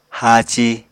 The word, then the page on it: haaci: Apatani